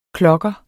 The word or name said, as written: klokker